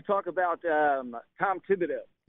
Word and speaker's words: Thibodeau: I think his name is Tom Thibodeau. – It is. The H is silent.